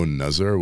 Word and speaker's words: Nazar: Yeah, but if you look on Instagram, it's just No Nuzzar, which is spelled N-A-Z-A-R.